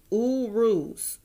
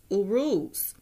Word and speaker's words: Uruz: And the letter U is pronounced Uruz, Uruz.